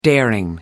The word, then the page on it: daring: American English